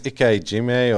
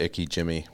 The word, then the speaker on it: ikijime: I think it's Ike Jime or something, but it's like IKIJIME, and I just say Ike Jimmy, which is probably just butchering the hell out of it.